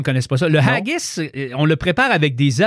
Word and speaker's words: haggis: Le haggis. – Oui, le haggis. – Peux-tu me l'appeler? – H-A-G-G-I-S. Je pense que je le prononce comme du monde, le haggis. – Oui, haggis.